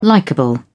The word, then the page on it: likable: British English